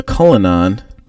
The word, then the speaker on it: Cullinan: It says the Cullinan.